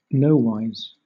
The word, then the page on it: nowise: British English